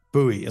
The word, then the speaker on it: Bowie: Bowie, is that how you say it? – Yes.